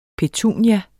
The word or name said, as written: PETUNIER